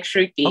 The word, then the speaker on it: Shruti: It's soft T. It's like Shruti.